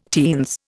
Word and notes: teens: Indian

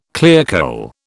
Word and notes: clearcole: British English

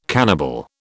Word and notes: cannibal: British English

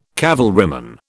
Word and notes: cavalryman: British English

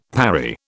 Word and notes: parry: British English

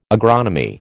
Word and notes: agronomy: American English

